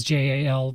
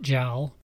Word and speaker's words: JAL: I always thought in the US it was JAL, but everyone over there calls it JAL.